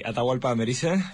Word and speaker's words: Atahualpa Amerise: Atahualpa Amerise from Amerise.